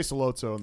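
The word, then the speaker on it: Sollozzo: And Don Corleone's perspective and kind of this interaction that they end up having with Salazzo is good. Am I saying that right, Salazzo? – They say Salazzo in the movie.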